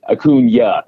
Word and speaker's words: Acuña: Acuña. It's got the little yeah at the end.